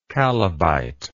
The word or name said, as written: chalybite